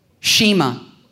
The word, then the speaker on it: Shema: I was told after first service that's kind of the right pronunciation, but a horribly terrible Southern Ohio accent from a guy who grew up in a Messianic Jewish church, and they had a prayer called the prayer of Shema, which is how you're supposed to pronounce it, I guess.